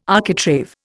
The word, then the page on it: architrave: Indian